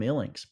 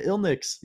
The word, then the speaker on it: Illinx: They come at me all the time. I used to get, oh, you're the guy from Linux Toys. It's like, what? No, I'm not. I'm not a computer company, actually. You're the Illinix. You're the Linux. You're the guy from Illinix. It's like, nope, none of those are correct.